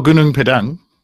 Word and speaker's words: Gunung Padang: Oh, Gunung Padang.